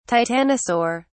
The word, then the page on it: titanosaur: British English